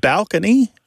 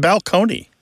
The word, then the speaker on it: balcony: My favorite change that I love to talk about when Martha and I go and do live events on the road is the word balcony, you know, like this place on a stage that you're going to look down upon the seats, used to be pronounced balcony.